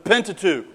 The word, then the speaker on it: Pentateuch: The Pentateuch, right? I'm teaching summer Hebrew, and these are bad teaching habits I fall into. The Pentateuch. Is a 5-book structure. And what do we call the Pentateuch?